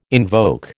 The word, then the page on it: invoke: American English